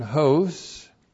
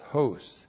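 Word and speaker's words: hos (ὡς): So we have, first of all, the conjunction hos. H long O-S, hos.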